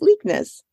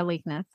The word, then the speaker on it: Leiknes: How do you say that? L-E-I-K-N-E-S. Leikness. – Yeah, Leikness, I would say.